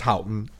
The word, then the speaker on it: Houghton: she wouldn't go for the Houghton. – In reality, I'm a little bit more intelligent than he. – But it's pronounced Houghton, and so there's two things that you can, there's two ways to get to the island from Houghton.